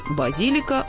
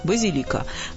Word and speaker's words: базилика: Практически все словари дают в этом слове двойное ударение. Хочешь, говори «базилика», хочешь «базилика». Два словаря, однако, придерживаются единственного варианта – «базилика».